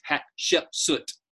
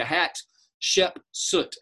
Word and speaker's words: Hatshepsut: And her name is Hatshepsut. So think of three syllables, hat, like a hat, Shepsut, Hatshepsut.